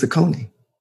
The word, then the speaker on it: Akoni: So when I clicked on that list, the first name on that list, the pronunciation was AH.KOH.NEE. It was Akoni.